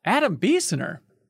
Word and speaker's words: Adam Biessener: And first time actually on the Min-Max Show itself, Adam Biesener.